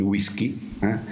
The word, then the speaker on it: whisky: Però quando è entrato in italiano questa parola ha prevalso la pronuncia toscana, che ha pronunciato wi come vi, e quindi il whisky,